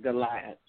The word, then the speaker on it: Goliath: Yeah, Goliath.